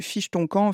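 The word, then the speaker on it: Fich-ton-Kan: Alors, Fiche ton camp ne s'écrit pas du tout comme il pourrait s'exprimer, parce que Fiche ton camp, finalement, si on le lit en français, ça fait Fiche ton camp avec un C-A-M-P. Non, ici, Fiche ton camp s'écrit un peu à l'asiatique, avec Fiche, donc comme le poisson, tiret ton, ben, T-O-N et tiret K-A-N.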